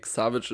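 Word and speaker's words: Szabics: Wie würdest du ihn denn aussprechen? – Šabić? – Nee, Šabić ist schon richtig.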